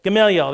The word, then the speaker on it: Gamaliel: Gamaliel,